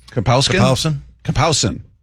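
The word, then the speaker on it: Kapowsin: Kapowskin? Kapowskin.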